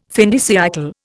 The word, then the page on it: fin de siècle: Indian